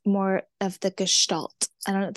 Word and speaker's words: Gestalt: So it's Gestalt. It's a German word.